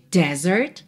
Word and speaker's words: desert: Se escreve D-E-S-E-R-T. Alright? D-E-S-E-R-T. A sílaba tônica é a segunda, então desert, desert. E esse S tem som de Z, right? Desert.